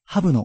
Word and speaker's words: HUB: お店は、 HUB と書いてハブの日比谷店。